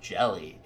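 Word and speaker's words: Hjelle: I think it's pronounced Jelly, H-J-E-L-L-E. I'm sure a lot of people say Sean Hajelli, but I think it's Sean. We gotta double check that.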